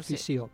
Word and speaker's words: fissió: La fissió, perfecte, fissió.